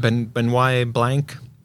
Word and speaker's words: Benoit Blanc: Benoit Blanc.